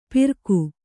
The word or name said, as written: ಪಿರ್ಗು